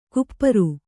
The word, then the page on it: ಖಾಪುರ: kannada